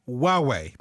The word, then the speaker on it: Huawei: And this week it's a flagship Chinese smartphone pronounced Huawei, and it's spelled H-U-A-W-E-I.